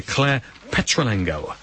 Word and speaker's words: Claire Petulengro: Mike Marshall is with us and we're also joined by Claire Petulengro. I bet you'd have said it wrong again. I'm no you said it perfectly.